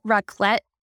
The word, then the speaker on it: raclette: Was it called raclette? – Yeah, something like that. Yeah, that's right. – Did I say it right? – Yeah, you did.